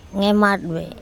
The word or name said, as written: ngemaTbe